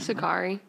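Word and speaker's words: Secare: Sakari. – Yes. Yes. Yeah. It does not sound how it's spelled.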